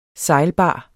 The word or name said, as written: sejlbar